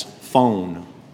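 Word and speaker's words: phone (φωνή): The word voice right there is the Greek word phone. We would pronounce that as phone.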